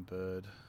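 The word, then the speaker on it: Byrd: I chose the name Bird. – And how do you spell it? It's B-Y-R-D, right? – Yeah.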